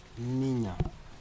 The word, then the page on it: ninja: Aneityum